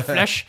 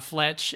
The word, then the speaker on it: Fletch: She played, every time she said his name, she pronounced it Flesh. She called it Flesh instead of Fletch.